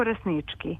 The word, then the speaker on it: Rasnički: Ja bih akcentovala kao što oni tamo govore. Deda Mijalko Rasnički.